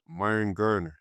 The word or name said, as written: Myron Gardner